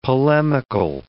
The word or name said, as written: polemical